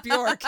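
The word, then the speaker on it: Björk: Yeah, it's Bjork. – It rhymes with jerk.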